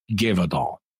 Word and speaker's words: Gévaudan: And all right, the accent's on the first syllable, so Gévaudan.